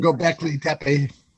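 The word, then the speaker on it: Göbekli Tepe: I'm going to write to you an email to you regarding um, Gumbleki Teki. Oh, sorry, Gumbleki. I was practicing that before I came on. I knew I was going to murder that pronunciation. Gobekli Tepe.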